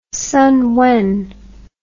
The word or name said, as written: Sun Wen